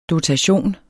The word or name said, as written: dotation